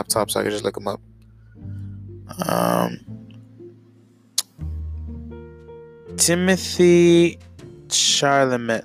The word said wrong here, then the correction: Chalamet: Charlemagne, Charlemagne, Charlemagne. I don't know how to pronounce his name. Let me let me do it on my phone. Timothy Chalamet. Timothy Chalamet.